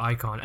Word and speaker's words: Aikon: Icon. And also just to clarify, it's called Icon, but it's spelled A-I-K-O-N. It's not spelled Icon like I-C-O-N, A-I-K-O-N.